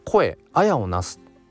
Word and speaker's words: 声文を成す: で何て読むかというと、えー、声「あやをなす」って読むそうなんです。